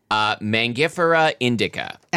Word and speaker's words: Mangifera indica: So would you pronounce the Latin name of this thing? – Mangifera indica. – Excellent.